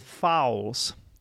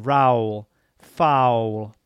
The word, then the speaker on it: foul: If you cannot remember the pronunciation of the word foul, then you can remember the word Raul. Raul Gonzalez. Remember that player, that excellent Spanish player? So it's the same pronunciation, Raul, foul, okay? And be careful how you spell it as well. It's F-O-U-L, a foul.